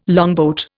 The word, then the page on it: longboat: Indian